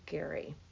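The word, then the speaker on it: Gerry: It's G E R R Y Barker. It is pronounced Gary.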